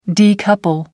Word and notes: decouple: American English

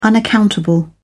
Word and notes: unaccountable: British English

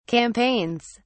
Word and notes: campaigns: British English

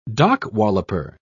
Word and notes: dock-walloper: British English

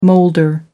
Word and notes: moulder: American English

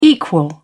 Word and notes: equal: British English

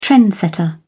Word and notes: trendsetter: British English

